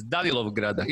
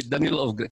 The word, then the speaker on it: Danilovgrad: Tu su Darko Bujić Bura, Tora i tu je E iz Danilovgrada ili Danilovgrad. Kako se izgovara, brate? Danilovgrad, kako hoćeš.